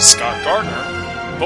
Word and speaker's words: Scott Gardner: You can find me, Scott Gardner, both on Twitter and Facebook.